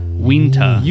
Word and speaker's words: Uinta: Winta. – Unit. – Winta. – Winta. Oh, Winta. It's like a Native American pronunciation.